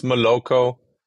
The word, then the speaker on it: Moloco: Did I pronounce it correctly? How do you guys pronounce the company name? – I guess Moloco, Moloco.